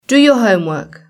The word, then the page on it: do your homework: British English